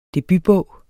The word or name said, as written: debutbog